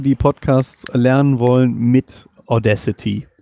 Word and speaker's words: Audacity: Genau, man spricht es richtig Audacity aus.